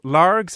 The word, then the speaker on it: Largs: Not bad from the boy from west of Glasgow, Largs, is that how it's pronounced? – That was it, yeah, seaside town.